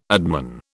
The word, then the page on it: adman: British English